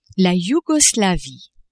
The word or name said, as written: Yougoslavie